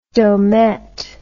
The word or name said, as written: domett